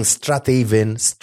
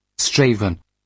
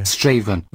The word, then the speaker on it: Strathaven: If you've been saying Strathaven, whatever you've been saying, it is completely incorrect.